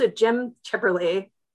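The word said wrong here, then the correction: Cipperly: who is Orange Cassidy, and it's Jim Sipperly, and I got like shouted at by my wrestling friends.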